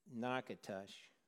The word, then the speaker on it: Natchitoches: And that's like the husband and wife who were driving through Louisiana and they approached a town that is spelled N-A-T-C-H-I-T-O-C-H-E-S and is pronounced Nakatush.